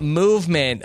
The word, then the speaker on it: MVMT: And those are our friends over at Movement Watches, MVMT, pronounced Movement.